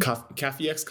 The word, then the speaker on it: Kafiex: Cafiex. Cafiex is K-A-F-I-E-X.